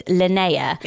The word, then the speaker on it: Linnea: It's spelled Linnea. So it rhymes with Freya, but it's Linnea.